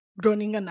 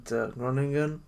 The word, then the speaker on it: Groningen: The internationals call it Groningen. I'm gonna try to pronounce it as the Dutch, but it's gonna be, I think, still way too far from it. But they call it Groningen.